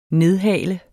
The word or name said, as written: nedhale